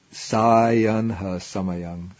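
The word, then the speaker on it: sāyanha samayaṃ: In the word, the word should be sayanha samayang. The first A is the long A.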